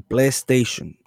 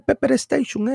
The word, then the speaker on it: PlayStation: Y un día la mamá de él dice, estaban jugando en el PlayStation. Y dice él, mira muchacho, el diablo, apágame el Pepper Station ese. – El Pepper Station. – Porque no saben ni pronunciar.